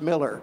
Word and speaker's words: Millar: Well, his name is Kenneth Millar, spelled Millar, pronounced Miller, Kenneth Miller.